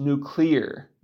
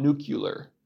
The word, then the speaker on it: nuclear: And by the way, it's clearly pronounced nuclear, not nuclear. Nuclear.